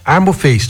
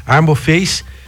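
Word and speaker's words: Harmoface: Não esqueça aí, anota o nome dessa clínica Armoface, tô falando certo, né? – Tá falando certo. – O H a gente esquece aqui. Armoface, que trata ronco e apneia, apneia do sono, que é uma coisa séria, sem você precisar comprar um CPAP.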